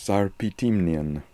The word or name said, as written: Sarpitimnian